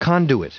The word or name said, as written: conduit